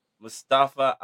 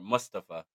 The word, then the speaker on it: Mustafa: We had Mustafa Ali, or Mustafa, that's how he wants it pronounced.